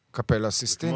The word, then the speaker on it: קפלה סיסטינית: סיסטין צ'אפל, איך אומרים את זה בעברית? – קפלה סיסטינית, לא? – קפלה הסיסטינית? – לא אסיסטינית, מה זה, אסיסטנטית? – סיסטין צ'אפל. – כן. קפלה סיסטינית.